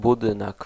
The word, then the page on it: bâtiment: France; [bɑ.ti.mɑ̃]